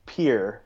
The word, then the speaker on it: Pierre: Yeah, well, clearly, because your book also told me that I've always pronounced its capital incorrectly. – South Dakota's capital is Pierre. – Oh, see, I got my Dakotas mixed up. – Pierre. North Dakota's is Bismarck, for capital nerds. But it's pronounced Pierre, South Dakota.